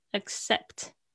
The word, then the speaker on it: accept: 这个 p 很轻的，accept 那个中间那两个 c，一个发 k 的声音，一个发 s 的声音，很好玩哈、所以呢，在念的时候，accept accept 那个 c 的声音好像抵制住了，发音位置好像要到，但是不爆破出来。